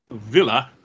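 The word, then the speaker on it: Wille: various people that used to work for Nerve have now defected, and they are trying to take down Nerve as Villa. That's with a W, but it's pronounced that way.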